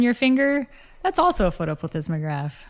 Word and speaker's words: photoplethysmograph: To give them a little credit, there's a little complexity there, but most people use something called a vaginal photoplethysmograph.